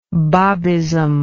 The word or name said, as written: Babism